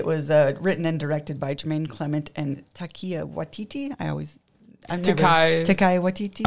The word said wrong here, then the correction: Taika: I butcher his name too, which is unfortunate because Taika.